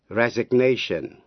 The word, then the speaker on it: resignation: Vậy ta biết động từ resign cũng theo lối phát âm như động từ sign, nghĩa là âm G cấm khi là động từ resign. Nhưng khi thành danh từ thì G có phát âm resignation.